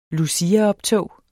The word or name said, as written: luciaoptog